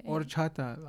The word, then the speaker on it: horchata: Horchata latte. We told you last time. – What is it? – Horchata. The H is silent.